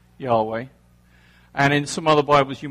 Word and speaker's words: Yahweh: So that means that actually it's quite difficult for us to really know what the true pronunciation is, but most folks believe that Yahweh is probably about right.